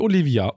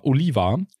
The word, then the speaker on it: Oliva: Nicht Olivia, Oliva. – Oliver. Oliver. – Aber das ist O-L-I-V-A.